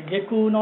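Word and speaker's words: 外宮: 外の宮と書いて下宮のね、路線所、神様の、まあ、お引越しですわ。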